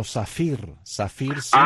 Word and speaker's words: Saffir-Simpson: No, porque yo oí que tú dijiste en la escala de Saffir-Simpson, esa yo la conozco como Saffir-Simpson.